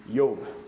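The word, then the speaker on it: Iyov: And then Yob. Because there's no J sound.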